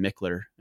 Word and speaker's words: Michler: That's at my personal Instagram profile, which is at Ryan Mickler. And my last name is spelled M-I-C-H-L-E-R.